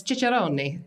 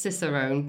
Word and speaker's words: cicerone: Well, if you ask Italians, it's chicharroni, but it ain't Italians, it's American. So, it's pronounced cicerone, but it does mean expertise.